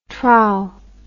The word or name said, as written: trou